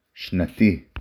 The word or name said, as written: שנתי